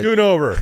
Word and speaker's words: Schoonover: Schoonover.